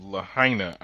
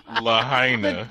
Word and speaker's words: Lahaina: Of Lahaina, I do believe is how they pronounce it.